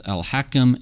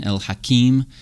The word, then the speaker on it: al-Hakim: Now, you will hear his name pronounced both al-Hakim and al-Hakim, because although the Arabic vowels are different and it's clear which one is which, when you transliterate this into English, write this in English letters, they both end up getting written the same way.